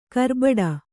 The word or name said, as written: ಕರ್ಬೆಟ್ಟು